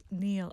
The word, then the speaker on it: nil: So I wrote that N-I-L, Neil, okay.